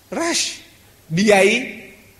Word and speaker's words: Rushdie: His name is spelled R-U-S-H. In English, is Rush. B-R-U-S-H, brush. C-R-U-S-H, crush. R-U-S-H, rush. D-I-E, die. D-I-E, die. Rush, die. Believe me,